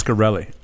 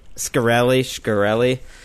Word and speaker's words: Shkreli: It's about a former Bloomberg reporter who lost her job, divorced her husband, and froze her eggs, all for Martin Shkreli. Is that how you say it? – I always thought it was Shkreli. – Shkreli.